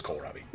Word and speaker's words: kohlrabi: It says kohl, like Kohl's, and then... Unless it's kohlrabi. – Such a girl. Like Kohl's. – Yeah. – Like the department store Kohl's. – It's spelled K-O-H-L.